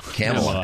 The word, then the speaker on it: Kamala: So how should we butcher her first name? What's the Kamala. Yeah. – Kamala. All right. – Yeah, call it Kamala. – Kamala, as in camel.